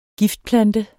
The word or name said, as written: giftplante